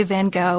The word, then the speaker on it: Van Gogh: And just a note, too, before we go any further, you can tell by now we're going to say Van Gogh, which is the standard American pronunciation.